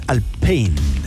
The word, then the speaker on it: Alpine: Pero yo sí, pero lo dicen mal porque la pronunciación real es más Alpine. No como Alpine. – Claro. Alpine, no es anglo. – No, es francés, po.